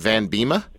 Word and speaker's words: Van Biema: Is it Van Biema? – Yeah.